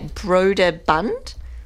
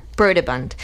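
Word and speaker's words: Brøderbund: So I think it originally started as a video game, which was released by Broderbund. I butchered that. Broderbund.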